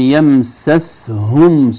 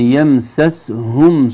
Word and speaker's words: يمسسهم: لم يمسسهم سوء، لم يمسسهم سوء، واتبعوا رضوان الله. السين متحركة بالفتح، سين ساكنة، الهاء من الحلق. البعض قد يستعجل يقول لم يمسسهم، يمسسهم لا تظهر الهاء بعد